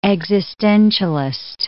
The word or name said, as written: existentialist